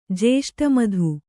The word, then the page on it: ಜೇಷ್ಟಮಧು: jēṣṭa madhu